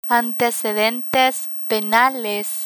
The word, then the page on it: antecedentes penales: spanish